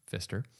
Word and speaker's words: Pfister: Directed by Christopher Nolan, written by Jonathan Nolan and Christopher Nolan, based on the novel by Christopher Priest, cinematography by Wally Pfister, pronounced Pfister. I read that.